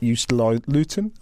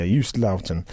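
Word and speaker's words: Joost Luiten: Jus Luton? I can't even say his name. I've picked him for Jus Luton. – Jus Luton. – Luton, yeah. – Yeah. – I think, anyway. – Yeah, is that the Dutch pronunciation? – One assumes.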